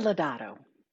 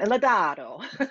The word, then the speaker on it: Lodato: Teresa Lodato. – Lodato. – It's, yeah. – It's Italian. Lodato.